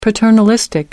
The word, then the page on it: paternalistic: American English